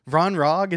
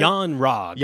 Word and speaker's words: Yon-Rogg: What are you calling him? – Vron Yogg? Isn't that how you say it? – Yon Rogg. – Yon Rogg? – Because it's a Y at the beginning.